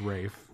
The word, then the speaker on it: Ralph: It's the old English pronunciation." That was it. Like, he just looked daggers through this motherfucker. It was just like, that's why it's Rafe.